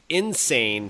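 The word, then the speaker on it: N. Sane: It's called Crash Bandicoot Insane Trilogy, and that's the letter N dot S-A-N-E, pronounced Insane Trilogy.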